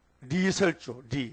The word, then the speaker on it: 리설주: 리설주, 북한 발음은 좀 이상하잖아. 우리는 오얀 이자, 이자 쓰잖아. 그 사람은 리라케, 리.